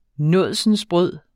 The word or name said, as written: nådsensbrød